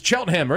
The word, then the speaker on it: Cheltenham: In this conversation, we start from the beginning, where he grew up in Cheltenham, not Cheltenham. – I have friends growing up who couldn't pronounce it correctly. It's Cheltenham.